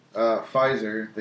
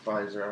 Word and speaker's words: Pfizer: So, Pfizer, I want to keep... – The P is silent. – I know, just like in pterodactyl or pneumonia.